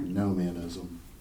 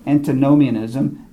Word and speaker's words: antinomianism: And in everyday life, we ended up with two things, antimonianism and did I pronounce it correct? – Antinomianism. – I think I said it wrong. Antinomianism and asceticism.